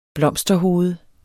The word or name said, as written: blomsterhoved